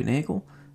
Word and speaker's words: Nagel: If you don't know me, my name is Robbie Nagel, and Nagel rhymes with bagel, so that's kind of how I got the name for this podcast.